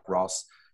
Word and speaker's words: Rohs: Now, the Ross is spelled R-O-H-S, but it's pronounced like Ross.